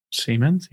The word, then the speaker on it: Siemens: Siemens, yeah.